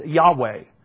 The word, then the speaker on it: Yahweh: Years ago, most people thought it was Jehovah. And so when you read the Old King James, that's the way it's translated, Jehovah. But most of the new discoveries and different manuscripts that exist back there, most scholars now are leaning now that it should be pronounced Yahweh.